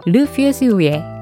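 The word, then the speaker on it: Le Fusil rouillé: 녹슨 총 정확한 제목은 르 퓨즈우에 이 정도로 발음한다고 합니다.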